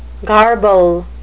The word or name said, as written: garble